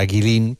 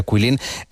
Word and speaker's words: Guilin: Se escribe Guilin, como si fuera Guilin, pero se pronuncia Guilin. ¿Está muy lejos, Enrique, de todas estas maravillas?